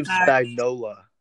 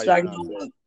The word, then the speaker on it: Spagnuolo: Steve Spagnuolo. That's how Spagnuolo.